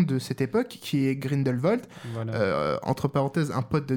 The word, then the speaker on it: Grindelwald: J'ai jamais su le prononcer. – Oui, Grindelwald.